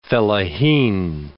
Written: fellaheen

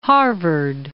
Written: Harvard

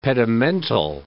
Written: pedimental

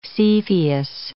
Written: Cepheus

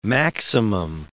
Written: maximum

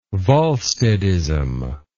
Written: volsteadism

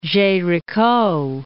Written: Gericault

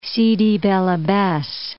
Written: Sidi Bel Abbes

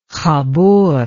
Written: Khabur

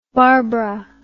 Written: barbra

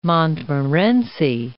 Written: Montmorency

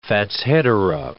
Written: fatshedera

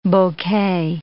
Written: bouquet

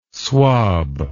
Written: swob

